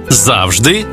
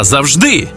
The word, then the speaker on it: завжди: 5 українських слів із подвійним наголосом. Байдуже, байдуже, завжди та завжди, мабуть та мабуть, також і також, помилка і помилка.